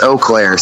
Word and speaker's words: Eau Claire: How do you say Eau Claire or Eau Claire? – It's Eau Claire. So okay. I think in French it means clear water.